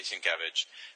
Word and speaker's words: Sienkiewicz: I can now officially say I know how to say Sienkiewicz because his Twitter handle is literally Sin, all caps, Kev, lowercase itch.